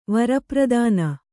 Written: ವರಪ್ರದಾನ

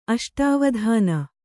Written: ಅಷ್ಟಾವಧಾನ

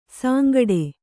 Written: ಸಾಂಗಡೆ

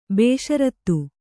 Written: ಭೂಸಾರತೆ

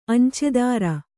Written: ಅಂಚೆದಾರ

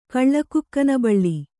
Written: ಕಳ್ಳಕುಕ್ಕನಬಳ್ಳಿ

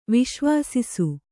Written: ವಿಶ್ವಸಿಸು

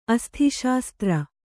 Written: ಅಸ್ಥಿಶಾಸ್ತ್ರ

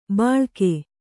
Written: ಬಳಾಕೆ

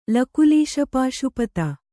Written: ಲಕುಲೀಶಪಾಶುಪತ

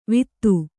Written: ವಿತ್ತು

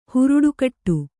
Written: ಹುರುಡುಕಟ್ಟು

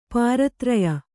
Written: ಪಾರತ್ರಯ

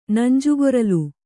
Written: ನಂಜುಗೊರಲ

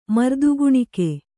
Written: ಮರ್ದುಗುಣಿಕೆ

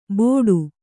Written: ಬೋಡು

